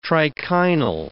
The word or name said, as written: trichinal